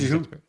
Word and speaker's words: Zhu: Tesla China President Tom Zhu, Z-H-U. I'm not sure. Do you know how to pronounce that? – I think it's Zhu. – Yeah. Zhu? Okay.